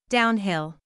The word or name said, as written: downhill